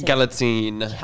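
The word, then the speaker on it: Galitzine: and Nicholas, what's his, how do you say his last name? You say Galatine. – Yeah.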